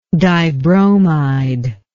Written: dibromide

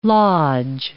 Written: lodge

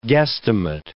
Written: guesstimate

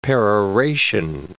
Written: peroration

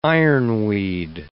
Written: ironweed

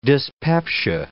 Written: dyspepsia

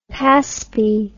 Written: paspy

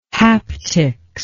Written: haptics

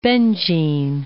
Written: Bedzin

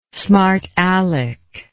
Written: smartaleck

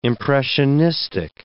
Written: impressionistic

